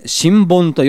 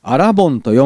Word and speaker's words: 新盆: 新盆と読んだんですけども、そしたらすぐに原稿を書いた新米の報道の方から電話がかかってきましてね、あれは信州ではアラボンと読むんですよとチェックされて初めて知ったくらいですからね。